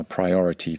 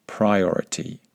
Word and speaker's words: priority: Make sure that you pronounce that first I vowel correctly. Priority, not priority. Just like we say Wi-Fi, not Wi-Fi. And bilingual, not bilingual.